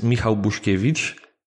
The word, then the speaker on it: Michał Buszkiewicz: So in the Americanized version, it looks like Michael Buskowitz. But I know your first name is pronounced Michał. So Michał, welcome to the show. – Yeah, hello. My name's Michał Buszkiewicz. It's the exact pronunciation, and I'm very pleased to join you in the podcast today.